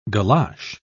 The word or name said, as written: galosh